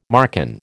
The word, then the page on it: Marcan: American English